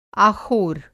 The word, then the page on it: आख़ोर: آخور